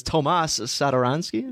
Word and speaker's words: Tomas Satoransky: Do you want to say his name? – I believe it's Tomas Satoransky. – Yeah, see, there you go.